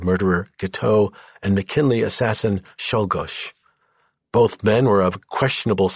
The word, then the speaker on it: Czolgosz: Of all the presidential assassins, Leon Czolgosz, pronounced Czolgosz, had the clearest motive.